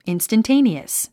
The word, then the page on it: instantaneous: ìnstəntéiniəs; インスタンテイニアス